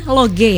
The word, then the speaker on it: Loge: Dari awalnya Loji, tapi ini tetap bacanya Loge ya. – Loge gitu. – Bukan Loji ya, Mbak ya.